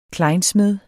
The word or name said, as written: klejnsmed